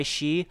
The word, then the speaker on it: Shih: and my last name She, SHIH. So James Y She.